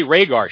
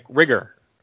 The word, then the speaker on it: Rhaegar: You know, how many shits did the Grandmaster take or whatever, and the other thing is that she she mispronounces the name. She doesn't say Rhaegar. She's like Rigger or yeah, she, right. she pronounced it like a Boston person.